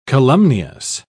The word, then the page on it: calumnious: British English